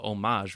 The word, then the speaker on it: homage: that H would be silent, right, in the French pronunciation. And of course, and then they talked about homage would be closer to the French pronunciation.